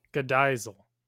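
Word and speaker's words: Godegisel: So there Gadizel. – We go. – I was pronouncing the extra G, which is apparently silent because apparently we just have to throw in extra letters.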